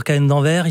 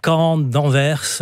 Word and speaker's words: Cahen d'Anvers: Alors ici en France, on dira bien sûr Cahen d'Anvers. Ici plutôt Caen d'Anvers. – Voilà. – La prononciation diffère selon les régions.